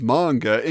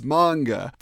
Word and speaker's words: manga: Just so you know, it's manga. Pronounced like conga, but with an M.